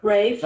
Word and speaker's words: Ralph: And I said Ralph Fiennes. It's Rafe, I guess.